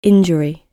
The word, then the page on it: injury: British English